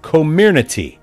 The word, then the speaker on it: Comirnaty: and they are going to market it under the trade name Comirnaty. That is the official pronunciation according to Pfizer,